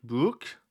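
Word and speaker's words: Brych: By the way, last week I called one of the referees in Germany Felix Birch. Turns out it's Felix Brück. I think it's how you say his name, because that's what Derek Ray said on commentary before this game, and it was the same referee.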